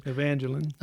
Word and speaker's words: Evangeline: Evangeline.